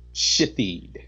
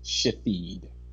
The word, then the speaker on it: Shithead: S-H-I-T-H-E-A-D pronounced Shahid. One more time. That's S-H-I-T-H-E-A-D. And let me just say that I saw this firsthand. And the mother had the nerve to get an attitude when someone made a mistake and actually pronounced it the way that they read it. Just think about that. She corrected them as if they should have known that it was pronounced Shahid.